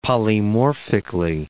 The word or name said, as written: polymorphically